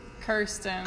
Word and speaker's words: Kirsten: I think you're saying my name. – Kristen? – Kirsten. – Kirsten. It looks like Kristen.